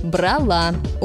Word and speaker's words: брала: Правильно говорить «брала». Ударение на последнем слоге.